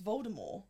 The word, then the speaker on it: Voldemort: Voldemort is pronounced Voldemort. – Yes.